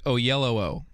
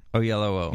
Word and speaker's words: Oyelowo: It is pronounced Oyelowo. – Oyelowo.